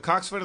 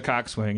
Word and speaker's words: coxswain: It's spelled coxswain, but it's coxswain. – Coxswain. – Like boatswain is bosun. A gun soul is a gunnel or whatever, you know. They shorten things.